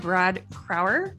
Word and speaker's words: Crauer: All right, so today we're going to welcome to the podcast Brad Crauer.